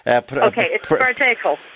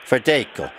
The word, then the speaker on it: Verdejo: Verdejo. – Verdejo. – Verdejo is a Spanish grape variety. It is not Pinot Grigio. So I just wonder where the argument is. Did the lady get Verdejo to drink?